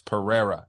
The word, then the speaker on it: Pereira: But if it's in the middle of their name, it's an R, like Alex Pereira.